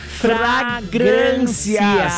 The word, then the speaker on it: fragrâncias: Fragrâncias. – São dois R's coladinhos, Eduarda, são fragrâncias.